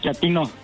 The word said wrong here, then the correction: chatino: No, chatino. – Es como chato, ¿verdad? Chatino. – Sí, azunero, como chato, chatino.